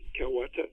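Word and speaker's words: Kow Ata: I've heard it pronounced Kawata, but it's K-O-W-A-T-A, Kawata.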